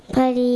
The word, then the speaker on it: พลี: พลีอ่านว่าพลี